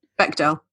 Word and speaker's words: Bechdel: Bechdel. – Bechdel, not like the sauce, like the.